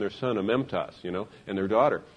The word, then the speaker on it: amemptos: Do you know in Greek the word blameless is amemptos, A-M-E-M-P-T-O-S is how they spell it, amemptos, which means without blame attached or without someone who is accused, they're unaccused by God.